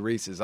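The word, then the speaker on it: Reece: My name is Reese. Like I was telling you earlier, down here there are actually quite a few Reese's.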